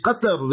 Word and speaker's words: Qatar: So it's Qatar, remember that in Arabic, but in English they mispronounce it.